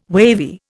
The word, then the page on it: wavy: Indian